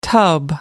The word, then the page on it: tub: American English